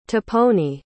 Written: tiponi

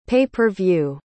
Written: pay-per-view

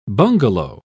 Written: bungalow

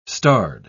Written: starred

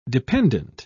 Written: dependent

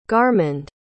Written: garmund

